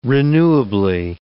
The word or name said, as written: renewably